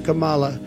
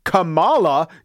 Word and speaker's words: Kamala: But if you say Kamala, you're throwing the whole experiment off. Of course, Trump has also tried this line in front of crowds, but with the other syllable emphasized. – Kamala Harris.